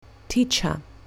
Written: teacher

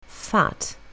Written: fat

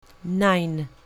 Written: nine

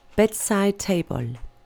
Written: bedside table

